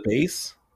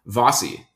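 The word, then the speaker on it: Vasi: Wait, so is her name Vase? – Vossi. Yeah, it's...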